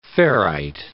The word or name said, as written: ferrite